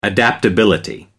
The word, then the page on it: adaptability: British English